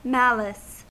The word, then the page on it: malice: American English